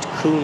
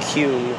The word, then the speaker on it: Kuhn: It faced Kuhn Road. Or Kuhn. K-U-H-N Road. Everyone has a different pronunciation.